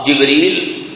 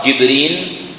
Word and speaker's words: جبريل: وجبريل تنطق جبريل أو في لغة العرب جبرين بالنون، أو جبرائيل أو جبرائيل بالنون، جبريل أو جبرين أو جبرائيل أو جبرائيل أو جبرائيل أو جبرائيل، يعني بفتح الجيم، إذن على الكسر أو على الفتح.